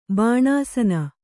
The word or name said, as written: ಬಾಣಾಸನ